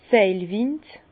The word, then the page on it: d' Seilwind: Bas-Rhin; Herrlisheim